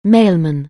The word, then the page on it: mailman: anglais